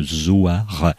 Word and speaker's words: Zoar: éditions Zoar, comme ça se prononce, Z-O-A-R,